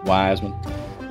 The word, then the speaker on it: Weisman: we're live, and I want to, Leah Weisman, you told me how to say it right. You said that in the South, they say it how? – Weisman.